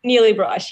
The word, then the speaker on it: Nili Brosh: Nili Brosh, yeah.